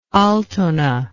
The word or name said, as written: altona